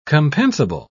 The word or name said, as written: compensable